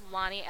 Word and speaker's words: Lani: Remember when I first started writing and, you know, I my name is Lonnie, L-A-N-I, which is a really difficult name to pronounce in any circumstance.